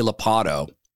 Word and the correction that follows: Ladapo: Lopato. Did I say that right? No, I'm Joe Biden. Ladapo. Ladapo.